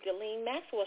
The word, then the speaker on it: Ghislaine Maxwell: I just happened to be watching a documentary on, I'm going to say her name right, finally, Ghislaine Maxwell.